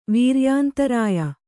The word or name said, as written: ವೀರ್ಯಾಂತರಾಯ